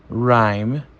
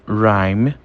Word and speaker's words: rhyme: Segunda palavra nós temos rhyme. Rhyme. Rhyme significa rima, tá? E mais uma vez, o H ele é silenciado.